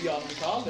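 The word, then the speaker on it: Jan-Mikael: Alle tror jo navn, altså mitt navn er jo Jan Mikael, det er sånn du uttaler det, men det står jo Jan Mikael, for alle tror jeg heter Mikael, vet du. Ja. Eller så sier de bare Jan, og like, nei, det er en bindestrekk der, du skal faen si begge to samme slengning, det er det som jeg husker navnet med.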